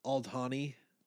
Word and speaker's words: Aldhani: Yeah, I think it's Aldhani. So it's kind of like a roll the D, man. I mean, don't be weird about it or anything, but just roll the D.